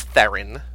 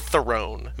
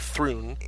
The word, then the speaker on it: Theron: It's Charlize Theron. Not Theron. Mm-hmm. Everybody says it's Theron.